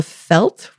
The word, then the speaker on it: veld: So in South Africa, we talk about the veld, which is spelled V-E-L-D, and the V is pronounced like an F, the veld.